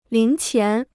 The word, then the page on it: 零钱: líng qián